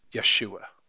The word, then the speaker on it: Yeshua: There is no J in Hebrew, so Joshua is properly pronounced Yeshua.